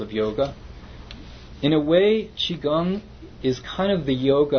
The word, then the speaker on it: Qigong: You keep saying Tiangong. Is that the same as... Is that how that's pronounced? – Qigong.